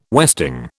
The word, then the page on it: westing: British English